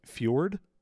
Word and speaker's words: foudre: Acai bowl milkstave IPA was fermented entirely in a large French oak fjord? – Sure. – Fjord. F-O-U-D-R-E. – You said it right.